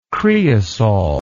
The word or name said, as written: creosol